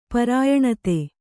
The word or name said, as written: ಪರಾಯಣತೆ